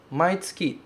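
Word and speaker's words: maitsuki (毎月): A frase diz assim, Maitsuki taishikan e ikimasu. Significa todos os meses vou para a embaixada. Esse kanji se pronuncia maitsuki, significa todos os meses.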